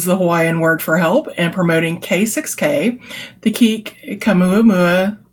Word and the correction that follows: Kamehameha: yeah, celebration. – King Kamehameha. – Kamehameha, yeah, sorry.